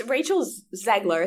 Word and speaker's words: Rachel Zegler: So it's Rachel Zegler.